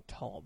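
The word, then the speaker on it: thal: So if a Neanderthal, thank you for saying tall, by the way. – Well, that's how it's pronounced. – Mm-hmm. – Look, in the original German...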